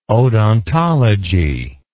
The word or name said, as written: odontology